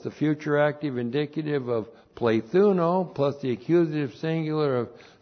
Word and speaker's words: plethuno: And then we have the multiplication verb, plethuno. Plethuno, the multiplication verb, P-L, long E-T-H-U-N, long O, plethuno.